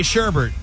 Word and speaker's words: Sherbet: Dad has his son say sherbet. The son says, Mackenzie? – Sherbet. – Which is how you say that. – Which is how one should say it because it doesn't have a second R.